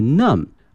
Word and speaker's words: numb: 首先看一下我们的舌头口腔，这个 tongue T-O-N-G-U-E，我们讲过后面这个发麻这个词很好玩，它的原型呢是 N-U-M-B numb， numb，注意一下后面这个 B 啊是不发音的哈， numb 表示啊身体的一个部位发麻了，比如说我们坐的时间太长了，我们的腿会感觉到 numb，如果说我们的一个手拿东西拿的太久了，也会感觉到这个 numb。